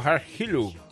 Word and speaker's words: Harp Helú: Oh, Harp Helú.